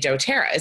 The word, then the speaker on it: doTERRA: doTERRA. Is that true? Am I saying that correctly? – You are saying that correctly.